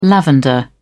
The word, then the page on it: lavender: British English